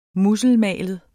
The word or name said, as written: musselmalet